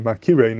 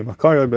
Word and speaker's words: מכירי: which is pretty clear that they're reading it Makire, not Makari. So that is mashma, the way to pronounce it would be Makire, not Makare.